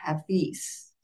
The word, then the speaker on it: Hafiz: So anyway, I ran across this Sufi mystic, Hafiz.